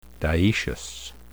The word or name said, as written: dioecious